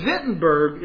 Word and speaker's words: Wittenberg: But know this, Wittenberg, it's spelled with a W, but it's pronounced with a V,